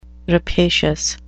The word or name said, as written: rapacious